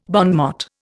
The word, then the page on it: bon mot: Indian